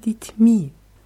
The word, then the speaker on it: детьми: С вашими детьми, bei вашими ist es auf A, bei детьми ist es auf dem letzten I. С вашими детьми.